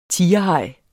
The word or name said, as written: tigerhaj